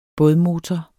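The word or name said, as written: bådmotor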